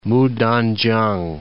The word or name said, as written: Mutankiang